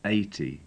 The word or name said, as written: eighty